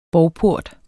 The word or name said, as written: bovport